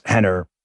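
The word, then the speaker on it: Rener: By the way, Henner Gracie, it's R-E-N-E-R. Please look them up. I know I said it with it. They pronounce their R's like H's. Hoyce, Henner, Horion. It's they're Brazilian, obviously. R-E-N-R.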